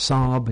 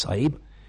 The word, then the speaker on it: Sahib: I called it Sahib. Depending on where you are in India, sometimes pronounced Sahib in the north, often more pronounced Sahib in the south.